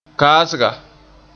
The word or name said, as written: kaasga